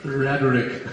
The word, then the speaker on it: rhetoric: Long ago, I took a course in now, what's the proper English pronunciation? In German we say rhetoric. – Rhetoric. – Quite different. Rhetoric. Rhetoric. You pronounce rhetoric.